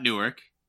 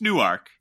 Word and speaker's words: Newark: It's not Newark. It's Newark. – Yeah. – It rhymes with park.